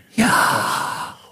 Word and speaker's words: Yahweh: So when you say his name, you don't say Yahweh, you breathe his name. Yahweh. – Yeah, breathe Yahweh. In, breathe out.